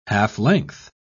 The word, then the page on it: half-length: British English